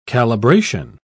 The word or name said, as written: calibration